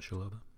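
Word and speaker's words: Chalobah: Oh, Chaloba. Chaloba? – Is that how you pronounce it? – Yeah, but it was after Hughes came off.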